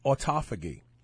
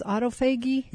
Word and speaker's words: autophagy: I always say autophagy, but the scientists I have on the show always pronounce it autophagy. So I got with the program.